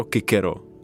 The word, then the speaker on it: Cicero: Mě by zajímalo, a to je otázka, kterou si kladu pokaždé, jestli se skutečně tehdy vyslovovalo Cicero Kikero, a jak vlastně máme možnost to zjistit? – To, to je úplně nepochybné, tohle, tohle je jasné a to se třeba, eh, to vyslovování co jako k eh, se úplně jednoduše dokáže tím, že řecké texty ze eh, stejné doby přepisují vlastně jejich jména s kapou řeckou.